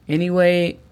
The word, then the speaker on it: anyway: La pronunciación fonética de anyway sería E-N-I-U-E-Y. Anyway.